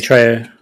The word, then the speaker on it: Trejo: But I think Danny, I always butcher his surname, Trejo. – Danny Trejo.